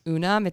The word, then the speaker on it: Unum: So one of them is called, I think it's pronounced Unum. It's UNUM.